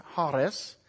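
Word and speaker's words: charis (χάρις): And the Greek word, you see it there, charis, it is pronounced charis.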